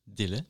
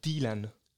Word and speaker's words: Dylan: Tout d'abord, je voulais dire que c'est très beau d'être ici, parce que vous êtes très sérieux, vous le prononcez très, très, très bien. Est-ce que tu peux le prononcer encore une fois? Bob? – Dylan. – Alors que c'est pour moi et pour la grande partie des italophones et des hispanophones, c'est Bob Dylan.